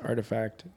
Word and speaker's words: RTFKT: I forget that it's the R. It's spelled RF. – I read that the other day on Twitter. Like, I just found out that RTFKT is pronounced Artifact.